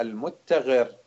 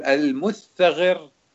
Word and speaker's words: المتغر: المتغر، شوفوا المتغر بالتاء المشددة مثنات ومثلثة، تقول المثغر أو المثغر أو المتغر كلهم نفس الشيء.